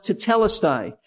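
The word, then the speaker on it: tetelestai (τετέλεσται): It is a Greek word pronounced tetelestai.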